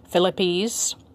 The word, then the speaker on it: thlipsis: Philippes actually starts with a TH. The spelling is THLIPSIS. Again, that's THLIPSIS. It's pronounced with a PH sound at the beginning, Philippes, which means to, again, crush or to squeeze.